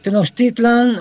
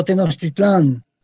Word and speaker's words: Tenochtitlan: La pronunciación de Tenochtitlán, Cuautitlán, Coyoacán, Teotihuacán, Tehuacán, etc., con el acento en la última sílaba, ha sido cuestionada y considerada como incorrecta, argumentando que la lengua náhuatl es una lengua con acentuación grave, que por tanto se debe de acentuar en la penúltima sílaba, y escribir sin tilde Tenochtitlan, Cuautitlán, Coyoacán, Teotihuacán, Tehuacán.